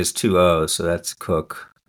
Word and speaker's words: Coke: The word, the surname Cook, C-O-K-E, looks like it's pronounced Coke, but is often pronounced Cook.